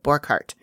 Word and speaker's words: Borchard: So, Borchardt, you know, and it's spelled Borchard. – Oh. – B-O-R-C-H-A-R-D, but he pronounces it Borchardt.